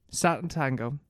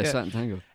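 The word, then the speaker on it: Sátántangó: Satan Tango. Yeah, that's how you pronounce it. – Yeah, Satan Tango.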